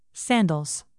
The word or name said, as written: sandals